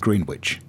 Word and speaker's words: Greenwich: Yeah, but that is pronounced Greenwich, but the one in New England is pronounced Greenwich. It's different emphases, different pronunciations, and it sort of makes sense that as, you know, different people get hold of a word, that it evolves.